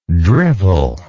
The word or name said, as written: drivelingly